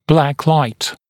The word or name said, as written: black light